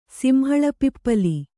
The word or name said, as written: ಸಿಂಹಳಪಿಪ್ಪಲಿ